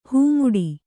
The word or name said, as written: ಹೆಮ್ಮಾಡಿ